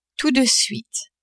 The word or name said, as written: tout de suite